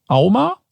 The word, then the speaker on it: AUMA: Und die Auflösung ist, im Jahr 2022 wurden laut AUMA, A-U-M-A, richtig ausgesprochen? – Ja, wirklich richtig.